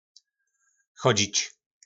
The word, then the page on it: Chodźič: Po našimu